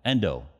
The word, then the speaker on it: Endo: Shusaku Endo. Endo, sorry, had his pronunciation of his last name.